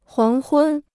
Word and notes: 黄昏: huáng hūn

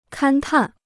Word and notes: 勘探: kān tàn

